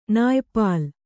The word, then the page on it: ನಾಯಿಪೆಲ್ಲ: nāyi pella